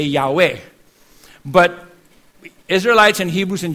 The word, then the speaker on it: Yahweh: We put A and E there from A, vowel of Adonai, and the vowel of Elohim. And that's why it's Yahweh.